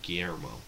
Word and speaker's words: Guillermo: I swear I'm butchering his name. – It's Guillermo. – Guillermo. – That's how I pronounce it.